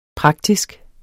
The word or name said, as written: praktisk